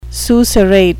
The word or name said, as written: susurrate